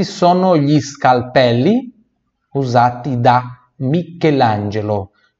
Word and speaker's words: Michelangelo: Com esse sono gli usar ti, então pronúncia pura do ti, da Michelangelo, CHE som de que por isso Michelangelo. Michelangelo.